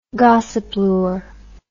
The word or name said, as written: gossyplure